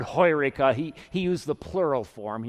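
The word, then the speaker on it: heureka (εὕρηκα): The word in Greek that Archimedes shouted when he discovered this law of physics was, in Greek, pronounced heureka. In English, we'd say eureka.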